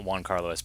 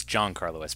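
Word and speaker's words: Giancarlo: By the way, the actor's name is not Juan Carlos Esposito. It's John Carlos Esposito, which I think is completely unfair because it's spelled Juan. It should be Juan. And it would give it more of a... – No, it's G-I-A-N.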